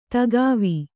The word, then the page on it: ದಿಖಾವು: dikhāvu